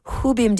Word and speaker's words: Rubem: This would be a good moment to mention that Edmonton's Rubem de Toledo is originally from Brazil, where the letter R at the beginning of a word is often pronounced more like an H.